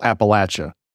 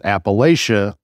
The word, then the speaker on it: Appalachia: So like you said, I grew up in rural Appalachia, right? That's how we pronounce it is. And one of the jokes that people will say is if you pronounce it Appalachia, I'll throw an apple at you.